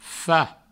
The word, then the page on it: fach: Provençal; fa